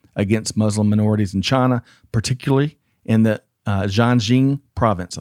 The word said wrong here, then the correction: Xinjiang: I think I got that right, too. – Xinjiang. – Close. Yeah. Gosh, I got the wrong emphasis on the wrong syllable.